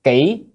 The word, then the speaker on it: Kỷ: À, không phải, Kỷ, chữ này là chữ Kỷ không đọc gì.